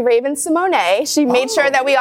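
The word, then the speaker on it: Raven-Symoné: But technically, if you want to pronounce it correctly, it's Raven Simone. But she said, if you continue saying Raven Simone, she said, don't trip.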